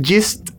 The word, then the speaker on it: Gist: Y el álbum se llama Gist, no sé cómo se pronuncia, G-I-S-T, Gist. Gist, Gist es, y está muy padre, son, hasta donde yo sé, son un grupo indie completamente y, pues...